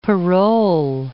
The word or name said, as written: parole